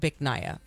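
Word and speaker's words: Bich Nga: So, yeah, once again, B-I-C-H-N-G-A. I mean, it looks amusing if you're reading this in English, you know, because in my head I'm like, yeah, doo-doo. But it's pronounced Biknaya, for the record.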